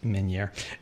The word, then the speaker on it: Meunier: And everybody doesn't know how to pronounce Meunier Chocolate Factory because it looks like Meunier. But it's Meunier.